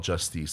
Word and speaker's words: Justice: you pronounce, you call it Justice. That's how the French call them.